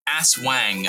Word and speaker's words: Aswang: Unfortunately, again, and the correct way to say that is Aswang.